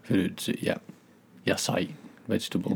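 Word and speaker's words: Yasai (野菜): Yasai. I honestly said yabai, which is terrible. Yasai.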